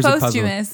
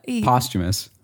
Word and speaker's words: posthumous: Posthumous. Posthumous. We've been saying it every single wrong way that you can possibly say it. – So we keep saying it wrong. So I'm like, that's how you say it.